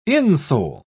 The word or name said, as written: insole